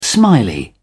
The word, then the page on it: smiley: British English